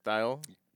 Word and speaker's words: Thayil: I'm saying that right? Thayil? – Yep. – Sorry, I didn't. Always seen it printed, never seen it said. – Well, you pronounce it correctly.